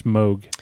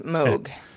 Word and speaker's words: Moog: I guess people are telling me it's pronounced Moog. – It is. I'm sorry. – Moog. – Yes, Moog.